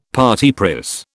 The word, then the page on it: parti pris: British English